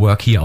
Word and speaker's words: Workhere.de: Workhere.de wird, wer es sich nicht denken konnte, wegen der Aussprache mit Doppel-E geschrieben nach dem K, also workeer.de, wird nur Workhere ausgesprochen, so wie arbeite hier.